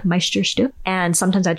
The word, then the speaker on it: Meisterstück: Here's how to say it. Meisterstück. Meisterstück.